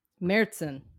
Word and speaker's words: Märzen: So, learning Märzen.